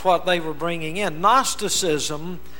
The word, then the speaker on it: Gnosticism: The word is spelled G-N-O-S-T-I-C-I-S-M. It looks like Gnosticism, but it's pronounced Gnosticism.